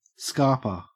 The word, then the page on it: scarper: Australian English